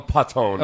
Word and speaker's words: Paton: It's P-A-T. I'm going to call him Patton. – Okay. – So, because it's spelled P-A-T-O-N.